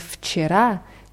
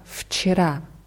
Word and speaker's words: вчера: Und bei dem Wort вчера kommt nach dem W gleich äh, der Konsonant Ч. Deswegen wird W als F ausgesprochen. Вчера.